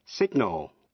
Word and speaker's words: signal: Tương tự, resign, R-E-S-I-G-N, nghĩa là từ chức. Danh từ, resignation. Những chữ tương tự sau đây có âm G phát âm. Signal là dấu hiệu.